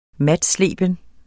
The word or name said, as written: matsleben